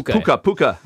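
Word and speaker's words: PucaTrade: I have no idea actually how to pronounce Puka. – It. – It's Puka. Like Pukachu. I always think of it's an offshoot.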